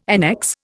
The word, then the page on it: annex: Indian